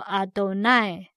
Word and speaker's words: Adonai: 万军之耶和华这个字是用军队的复数 Tzevaot Tzevaot，万军之耶和华就是 Adonai Tzevaot，耶和华我们念作 Adonai，然后 Tzevaot，所以合起来就是 Adonai Tzevaot，万军之耶和华。